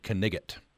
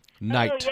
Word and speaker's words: knight: So this is just taken straight from popular culture, but they pronounced the word knigget, knight. – Oh, yes. – Is that true, or are they making that up? – So the word used to have that initial cluster, and we've just systematically lost all of those.